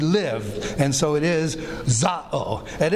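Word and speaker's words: za'o (ζάω): The word live, we saw last time, is the word za'o. Z-A-O. That's the verb form. Here we actually have zoe. This is Z-A-O, the noun form.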